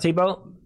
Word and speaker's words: Thibault: there it is, Mr. Thibault. Pronounced Tebow.